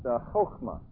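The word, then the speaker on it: chokhmah (חָכְמָה): It would be pronounced chokhmah, and it's a word that means skill.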